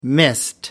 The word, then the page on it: mist: British English